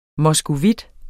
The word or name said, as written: moskovit